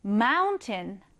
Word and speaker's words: mountain: 그런데 강세를 앞에 강하게 넣어주면서 뒷부분은 약하게 발음이 됩니다. 그래서 mountain과 같이 발음하진 않고요, mountain. 네, 이렇게 발음을 하는 것이죠.